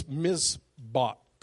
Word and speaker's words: mizbach: So, the Hebrew word, John, for altar is, it's mizbach, and it's spelled M-I-Z and then beach.